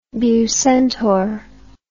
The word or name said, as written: bucentaur